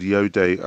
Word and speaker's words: Iode: It's spelled I-O-D-E. It sounds more like the Star Wars character Yoda, but it's all Yode.